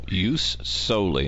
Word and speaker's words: jus soli: Jus soli, and that's actually spelled with a J, right? J-U-S-S-O-L-I, right? – Yeah, it depends on how you anglicize the Roman, but you could be jus or jus soli.